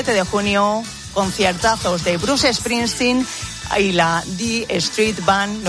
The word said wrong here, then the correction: E Street Band: no sé si lo he pronunciado bien, Marco, y eso, E Street Band, que bueno, la verdad es que es un lujazo tenerle aquí en España.